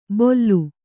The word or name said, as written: ಬೊಲ್ಲು